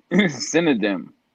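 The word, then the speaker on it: Cinedigm: No, it's C-I-N-E-D-I-G-M. – Yeah, which kind of looks like Cynodym, but it's just I think the G is silent. – Okay, just Cynodym.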